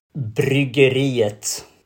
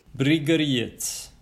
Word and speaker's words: Bryggeriet: So Brigriets, how do you pronounce it properly? Actually, how would you say it in Swedish? – Bryggeriet. – Bryggeriet.